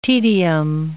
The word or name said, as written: tedium